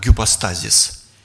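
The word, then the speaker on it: гипостазис (ὑπόστασις): Знаете, ипостась, точнее, греки произносили примерно так, гипостазис, использовали в таких случаях.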